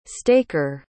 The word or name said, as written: staker